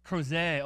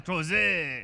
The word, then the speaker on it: Crozet: Is that how you say it? Crozet, oh, oui, bonjour, French, oh, yeah, Crozet.